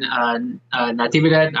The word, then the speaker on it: Natividad: You know, especially against a tough fighter like Kevin Natividad. I don't know if I butchered his last name. – You got it, man.